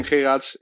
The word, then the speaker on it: Geraedts: So I'm uh, so I'm Tim Tim Geraedts in uh, in Dutch. I won't make you pronounce it correctly. Uh, but I'm um, uh, uh Tim Geraedts, uh, currently living in Amsterdam.